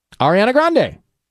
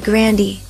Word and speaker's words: Ariana Grande: Ariana Grande. – Okay. – My grandpa said Grandy.